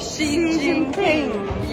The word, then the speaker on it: Xi Jinping: Xi, Xi Jinping.